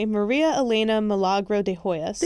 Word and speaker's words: Maria Elena Milagro de Hoyos: And then one day, Maria Elena Milagro de Hoyos.